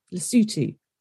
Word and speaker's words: Lesotho: So the 8 Africans are Eswatini, formerly known as Swaziland, Ghana, Kenya, Lesotho, Rwanda, South Africa, Tanzania, and Zambia. I know everyone's thinking most of those are very easy to pronounce, but Lesotho does not look how it's said.